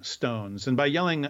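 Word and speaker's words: Jelling: It's yelling with a J. So it looks like gelling stones. – Oh, okay. – But J is generally pronounced in Scandinavian languages.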